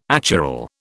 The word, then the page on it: achiral: British English